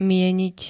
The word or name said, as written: mieniť